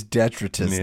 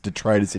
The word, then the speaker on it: detritus: In my day, it was detritus. Yeah. Now the preferred pronunciation is detritus.